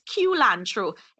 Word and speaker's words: culantro: It's not cilantro. So it's it's pronounced culantro.